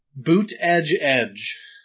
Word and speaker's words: Buttigieg: Boot Edge Edge.